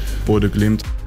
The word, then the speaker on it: Bodø Glimt: Und viele dachten immer, das wird Bodø Glimt ausgesprochen. Mhm. Vincent Kompany, Bayerns Trainer, sagt aber, nee. – Ich finde es immer gut, dass man das deutlich sagt und auch ausspricht. Bodø Glimt. – Bodø Glimt heißt das nämlich, das Ganze.